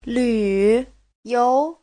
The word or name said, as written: lv3you2